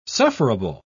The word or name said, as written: sufferable